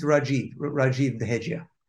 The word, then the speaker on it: Rajeev Dehejia: That's pretty good though. It's Rajeev Dehejia.